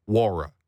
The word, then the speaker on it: Nwora: Jordan Nwora, by the way, I was specifically told by Raptors PR, the N is silent, okay? So it's Nwora.